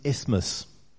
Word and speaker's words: isthmus: I've got a question for you, and the question is, what is an isthmus? Did I say that right? Neck? Yep.